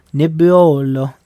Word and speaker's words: Nebbiolo: And one thing that's not faking right now is the delicious flavors and natural taste in the Lange Nebbiolo. Did I say that right? – Yeah. – Nebbiolo.